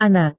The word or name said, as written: anak